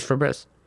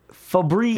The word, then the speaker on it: Febreze: It's Febreze. – Febreze. – Yeah, you say Febreze, but there's only one E in the middle. Thanks. – Even in the commercial, they pronounce it Febreze. – Yeah, but it's not spelled like that.